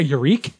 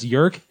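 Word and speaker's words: Yeerk: I think it's actually pronounced Yurk. Did you not read Animorphs as a kid? – Oh, yeah, Yurk.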